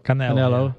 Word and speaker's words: Canelo: Canelo.